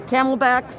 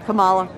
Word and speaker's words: Kamala: I don't think Biden's going to make the 4 years and the camelback, forget it. – The camelback, who's that? – Kamala.